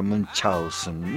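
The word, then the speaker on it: Münchhausen: En realidad se pronuncia Munchausen, Munchausen, que algún alemán me lo desmienta.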